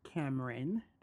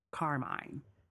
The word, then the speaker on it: Carmine: So this is the Carmine Davis Show, and I'm glad that you guys are listening. Learn to say my name. It's not Cameron Davis. It's not Carmen Davis. Carmine, exactly how it's spelled.